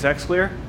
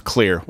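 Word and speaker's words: Xlear: Hootie didn't take his X clear? – I love that our correspondent didn't, it's pronounced clear with an clear.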